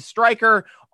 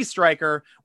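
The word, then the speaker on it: Stryker: G Striker, on Twitter and Instagram at SN Striker, Striker spelled with a Y.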